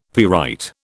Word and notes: pyrite: British English

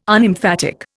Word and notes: Unemphatic: Indian

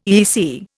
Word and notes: EEC: Indian

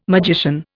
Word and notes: magician: Indian

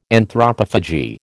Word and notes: Anthropophagy: American English

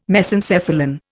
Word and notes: Mesencephalon: Indian

